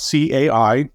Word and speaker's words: CAI: but we call it CAI. You might hear people try to pronounce it as one word, but it's technically three letters.